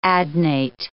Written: adnate

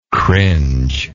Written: cringer

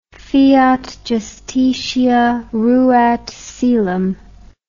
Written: fiat justitia ruat caelum

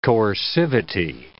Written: coercivity

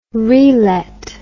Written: relet

